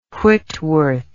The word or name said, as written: Whitworth